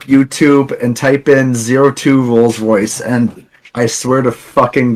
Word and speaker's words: Rolls Royce: Sorry, the 02 Royal Royce. – Rolls Royce. The vehicle. Royce. The car. – My bad. I always pronounce that fucking shit wrong.